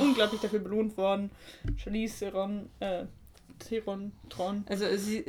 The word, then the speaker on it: Theron: Ähm, aber eigentlich wird es äh, wohl in Afrikaans Thron ausgesprochen. Ich kann es aber auch nicht ganz. Es ist ein Thron.